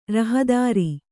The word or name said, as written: ರಹದಾರಿ